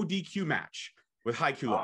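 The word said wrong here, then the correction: Hikuleo: I hope I'm saying it right. Haikulo. I think I am. I'm not saying it right. – No, even when I first met him, like I didn't know how to, I never said his name, you know, until I had to ask someone, but it's Hikaleo.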